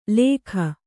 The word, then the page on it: ಲೇಖ: kannada